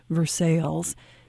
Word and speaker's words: Versailles: You know, this town in Indiana that you write about, in Illinois, we have a town by the same name, and we pronounce it Versailles. Is that how it's pronounced in Indiana? – Yeah. Mm-hmm.